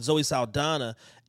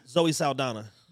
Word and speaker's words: Zoe Saldana: Zoe Saldana. – That's the Colombiana? – Yeah. – I always thought her name was Salamander.